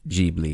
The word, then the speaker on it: Ghibli: I think they say Ghibli, even though it's supposed to be pronounced Ghibli. – I think it is, yeah. – Like, the Italian plane that it's named after is called the Ghibli.